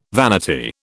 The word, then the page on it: vanity: British English